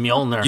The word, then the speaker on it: Mjolnir: How do you say Mjolnir? – You just know you Mjolnir.